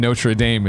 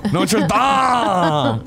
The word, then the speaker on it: Notre Dame: The Dame in Notre Dame is pronounced like Greg says Dom in Fast and Furious. Dom! Notre Dame!